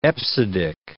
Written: EBCDIC

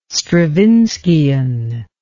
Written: Stravinskian

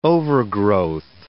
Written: overgrowth

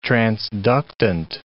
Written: transductant

